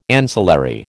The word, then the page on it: ancillary: American English